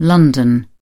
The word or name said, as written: London